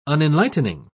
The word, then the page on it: unenlightening: British English